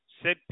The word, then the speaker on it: Sid: Sid Peterson. – Spell it. – Sure. It's S-I-D.